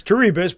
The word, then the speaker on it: Terebus: Because you put a T in front of it, you get Terebus. That's our last name. – Yeah. Oh, is that how you say that, Terebus? I think I said Terebus. – It's Terebus, but, it's proper pronunciation of Erebus is Erebus.